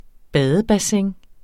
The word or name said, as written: badebassin